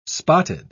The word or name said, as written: spotted